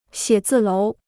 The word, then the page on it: 写字楼: xiě zì lóu